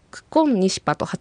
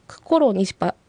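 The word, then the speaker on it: ククンニシパ: そうですね、ここはコロの R とニシパの頭文字の N、 RN が重なって、この場合はその前の R は N の発音になりますので、クコロニシパよりはクコンニシパと発音される方が自然と言えます。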